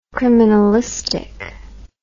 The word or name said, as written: criminalistic